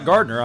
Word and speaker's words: Gardner: And hey, you can friend me, Scott Gardner, on Facebook too.